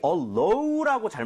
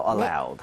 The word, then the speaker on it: allowed: 그런데 주의해야 될 게 많은 분들이 allow라고 잘못 발음하시는 경우 많아요. – 네. – 근데 얘는 allowed가 되겠습니다. 알아도 연음에서 틀릴 수 있으니까 주의하셔야 돼요. allowed.